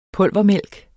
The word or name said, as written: pulvermælk